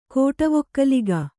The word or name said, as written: ಕೋಟವೊಕ್ಕಲಿಗ